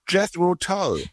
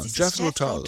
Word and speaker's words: Jethro Tull: Okay, dann kommen wir jetzt zu, jetzt musst du mir wieder helfen bei der Aussprache, ich spreche die immer falsch aus, Jethro Tull? Tull? Tull? – Ach so, Jethro Tull, ja, Jethro Tull. Tull. Ja. Genau.